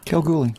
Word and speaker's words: Kalgoorlie: Kalgoorlie.